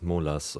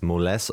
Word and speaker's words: Moles: Also es ist ja, keine Ahnung, in Deutschland denkt man vielleicht dann, es heißt Molas oder so. Wobei wir ja englischsprachige Musik machen. Deswegen ist Moles auf jeden Fall die richtige Aussprache.